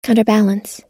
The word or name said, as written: counterbalance